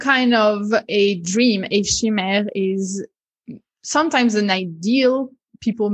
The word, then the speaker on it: chimère: How would you pronounce it as a French? – So in French, we would say chimère,